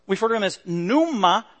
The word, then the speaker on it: pneuma: It's got a P in Greek. Pneuma, pneuma. And then in English, it's pneuma, silent P,